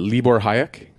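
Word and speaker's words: Libor Hájek: Is it Libor Hayek? – Yep.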